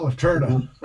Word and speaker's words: Lanterna: Did I pronounce that right? – Yeah, okay. Licher. – It's no, Licher, I'm sorry, but what about Lanterna? – Lanterna, yes.